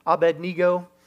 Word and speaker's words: Abednego: It's about three guys named Shadrach, Meshach, and Abednego. And that's really how you say it.